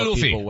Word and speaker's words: Luffy: It's Luffy, right? Monkey D. Luffy. – I'm watching the game now. Monkey D. Luffy.